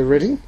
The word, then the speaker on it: Reading: Reading, we would read it Reading, but the British say Reading.